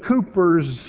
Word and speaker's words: Cowper: His last name is spelled C-O-W-P-E-R. I always thought it was Cowper.